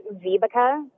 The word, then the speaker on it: Vibeke: So my name is Vibeke, and it's spelled V-I-B-E-K-E.